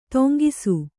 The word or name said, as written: ತಂಗಿಸು